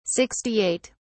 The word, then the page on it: 68: British English